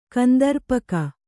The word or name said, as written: ಕಂದರ್ಪಕ